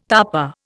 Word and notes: tapa: Indian

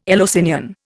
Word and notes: Eleusinian: Indian

